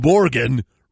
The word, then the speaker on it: Morgan: As lawyers, court staff, and deputies watched in disbelief, Superior Court Judge James Morgan, rhymes with Oregon, questioned the 24-year-old unemployed Charlotte Mann.